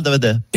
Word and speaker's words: Darmanin: Pardon, vous avez mal prononcé mon nom. Mon nom, c'est Gérald Darmanin. – Darmanin. Oui. – Darmanin, D-A-B-A-D-I-N.